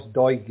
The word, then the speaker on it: Doig: How do you pronounce it? Is it Doig? – Yeah, Josh Doig, yeah.